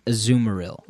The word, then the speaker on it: Azumarill: Azumarill.